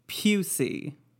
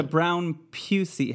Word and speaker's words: Brown-Pusey: I actually called them because I wanted to make sure I was saying it right. And it's Pusey. It's the Brown Pusey House. But it's Brown hyphen P-U-S-E-Y.